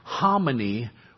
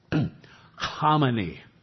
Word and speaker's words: Khamenei: His name is pronounced like Khamenei with a guttural CH in front of it. So it's Khamenei.